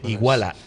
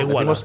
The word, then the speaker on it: Iguala: De Iguala. igual ¿Lo pronuncia así? ¿Lo pronuncio bien? no, igual. Iguala.